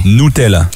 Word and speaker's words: Nutella: Ben, fait qu'à partir de ce matin, il faut prononcer nous. Fait que là, avertis tes amis, ta famille. – Tout le monde. – Nutella.